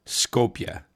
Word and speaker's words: Skopje: Skopje. And yes, that is how you pronounce a city that's spelled S-K-O-P-A-E. The J in that part of the world is a Y, like our Y. I've seen Skopje described as Europe's strangest capital,